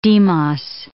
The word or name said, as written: demos